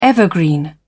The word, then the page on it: evergreen: British English